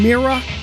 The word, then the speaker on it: Mira: 1967 on Mira, M-I-R-A, as opposed to the Boston pronunciation of Mirror.